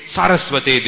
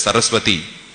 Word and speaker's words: Saraswate: Like, that's why Prabhupada in his pranam mantra, which he coined himself, he says, Namaste Saraswate Deve. Of course, sometimes devotees pronounce it as Saraswati, but it's Saraswate.